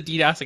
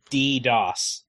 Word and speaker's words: DDoS: Also, I have a bone to pick with DDoS. – Yeah, because you don't like that first D, do you? – Yeah. – Yeah, I know. – When you pronounce it, it's silent.